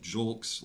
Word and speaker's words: Julks: It's an awkward way to say it, but I saw an interview with the man, and he pronounced it Julks like it's written J-U-L-K-S.